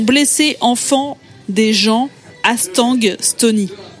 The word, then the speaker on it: Stony: Stony, ça s'écrit S-T-O-N-Y, je me rends compte en le disant que ça se trouve, vous comprenez Estonie comme le pays.